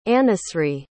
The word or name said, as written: anusree